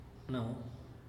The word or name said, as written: nau